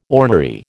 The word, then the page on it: ornery: American English